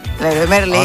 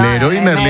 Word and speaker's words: Leroy Merlin: Mensajito, ¿cómo se dice? ¿Leroy Merlin o Leo Guamerala? – Leroy Merlin. – Amigas, ¿son franceses? – No, no, Leroy Merlin. Merlin, Leroy Merlin, toda la vida.